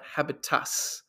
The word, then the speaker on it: habitus: So one of the sociologists, his name is Pierre Bourdieu, he refers to this idea of habitus, not habitats. And yes, I'm pronouncing it right, habitus.